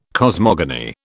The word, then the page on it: cosmogony: British English